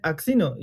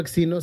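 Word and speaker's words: Aczino: Entonces, yo siempre le he dicho axino, sé que se pronuncia asesino, pero me gusta hacerla la mamada, porque me gusta Ahí, no se pronuncia asesino de ninguna manera.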